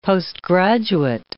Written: postgraduate

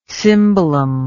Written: cimbalom

